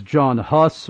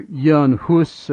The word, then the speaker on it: Jan Hus: John Huss, or Jan Hus, as he is probably more accurately, his name is more accurately pronounced, the Swiss pre-Reformation reformer.